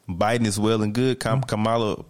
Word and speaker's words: Kamala: Yeah, Kamala Harris, yeah.